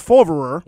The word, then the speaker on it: Favre: Before you know, Brett Reggie F- and Brett uh, Favre. Uh, Brett, no, no, you got to say it like the old commissioner, Brett Favre.